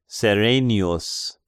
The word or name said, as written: Serenius